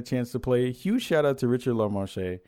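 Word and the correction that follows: Lemarchand: I'm probably saying his name wrong. It's Richard LaMarchand. I'm saying his name right. I always make it super French for no reason.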